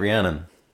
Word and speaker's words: Rhiannon: That's right, Rhiannon.